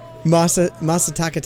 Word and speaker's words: Masataka: Nika, obviously, we know it's one of the big distilleries in Japan, started by Masaharu Takatsura. Worked for Suntory for 10 years. – I'm glad you corrected the pronunciation, because fuck, I'd have been so backwards. – Masataka Takatsura, he started with the Suntory Distillery.